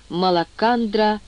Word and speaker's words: Малакандра: Малакандра — земля, то есть планета, как целая. Потом можно будет выяснить, что значит «малак». После «к» не произносится «х», — отметил филолог, сделав первый шаг в изучении малакандрийской фонетики.